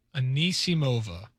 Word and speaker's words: Anisimova: Anisimova.